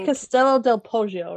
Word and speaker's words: Castello del Poggio: Stello del Poggio. What do you guys think? Moscato. – I would say Castello del Poggio, right? – Yes, that sounds yeah.